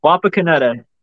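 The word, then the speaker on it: Wapakoneta: How do you say it? – Wapa, Caneta. You were right. Yep. – Wapa, Caneta.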